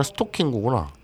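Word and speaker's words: ストッキング: 스타킹구가 아니라 스토킹구구나. 예, 스토킹구. 그럼 스토킹구하고 스토킹구하고 같네? 어, 아니죠. 스토킹은 스토킹구. 토가 장음입니다. 스토킹구.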